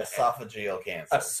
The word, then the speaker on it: esophageal: Esophageal cancer.